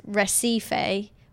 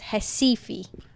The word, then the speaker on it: Recife: But if you look at the word, it looks like it says Recife, but it's Recife. – Because their R's are pronounced as H's. – And their E's are pronounced as I's.